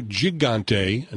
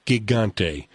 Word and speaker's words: Gigante: And I used to call it Sabado Gigante until my daughter took Spanish in high school and said, no, there's no soft G in Spanish. It's Gigante.